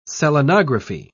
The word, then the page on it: selenography: British English